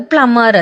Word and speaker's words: plumber: ಪ್ಲಂಬರ್, ಪ್ಲಂಬರ್ ಅಂತ ಹೇಳ್ತೀವಿ, but ಅದು ಉಚ್ಚಾರ ಆಗಲ್ಲ, ಅದು ಪ್ಲಮ್ಮರ್ ಅಂತ, PLUMBER, ಪ್ಲಮ್ಮರ್ ಅಂತ ಹೇಳಬೇಕು ನಾವು ಕರೆಕ್ಟಾಗಿ ಅದರ ಪದಗಳನ್ನ ಒಂದು ಬಿಡದೆ ಹೇಳಬೇಕು ಅಂತ. ಕನ್ನಡದವರಾಗಿರೋದಕ್ಕೆ ಪ್ಲಂಬರ್ ಅಂತೀವಿ, ಬಟ್ ಇಟ್ಸ್ ರಾಂಗ್, ಪ್ಲಮ್ಮರ್ ಅಂತ.